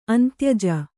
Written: ಅಂತ್ಯಜ

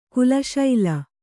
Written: ಕುಲಶೈಲ

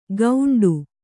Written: ಗೌಂಡು

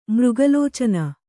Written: ಮೃಗಲೋಚನೆ

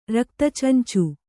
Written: ರಕ್ತಚಂಚು

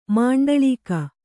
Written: ಮಂಡಳೀಕ

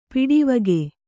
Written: ಪಡಿವಾಘೆ

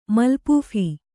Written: ಮಲ್ಪೂಫ

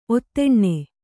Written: ಔತನ

